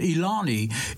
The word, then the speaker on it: Ilani: It looks like Elaine. I think she pronounces it Ilani.